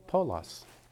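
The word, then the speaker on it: Paulos (Παῦλος): So, how do we say this name? Paulos. And it means small.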